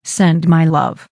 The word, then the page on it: send my love: American English